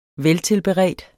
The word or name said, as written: VELTILBEREDT